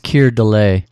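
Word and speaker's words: Keir Dullea: Yeah, and there was a movie in 1980, and it stars Bud Cort and the guy from 2001: A Space Odyssey, whose name I pronounce as Kier Duella, but I don't know how you really say it. – I think it's Kier Delay. – Kier Delay. Okay.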